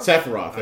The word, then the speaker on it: Sephiroth: I guess I pronounced his name. Serpentoff? You remember you were talking about? There's Cloud and then... – Sephiroth? – Sephiroth. There we go. I just pronounced his name wrong.